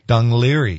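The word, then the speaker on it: Dún Laoghaire: Dunleary Institute of Art, Design, and Technology in Ireland.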